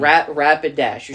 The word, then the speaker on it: Rapidash: Donphan, Beedrill, Rampidash, number one. – Rapidash. Rampidash. Rapidash. You're saying ramp. Rapidash.